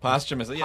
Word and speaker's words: posthumously: And so to have that kind of posthumously—is that how you say it? – Posthumously, posthumously come out is very surreal.